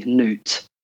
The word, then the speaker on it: Noot: So the way I spell it is N-O-O-T, Newt.